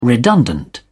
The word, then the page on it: redundant: British English